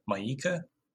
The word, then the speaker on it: Mieke: One thing to point out from the questions is that the dating coach, it's spelled M-I-E-K-E, Maika, she asks, how important is sexual loyalty to you?